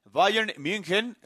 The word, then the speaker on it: Bayern München: El Barcelona estará recibiendo al Bayern München, lo pronuncié bien. – Muy bien, muy bien. Usted muy bien.